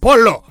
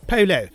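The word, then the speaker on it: Pelé: Me, the one thing he taught me to pronounce, I mean, you know, not Polo. Like, it's Pele.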